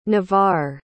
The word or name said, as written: navar